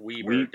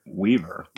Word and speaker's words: Weber: Weaver or Weber? – Weber. It's pronounced Weaver. – Weaver. – With a B.